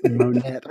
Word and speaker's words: Monette: This town is called Monette, but it's spelled like Monet. – No, it's not. There are two T's. – I can see this is Monet where they are. That's Monette.